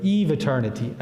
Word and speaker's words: eviternity: but what in theology we call eviternity or eveternity. Eviternity is the proper pronunciation.